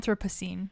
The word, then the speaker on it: Anthropocene: Anthropocene.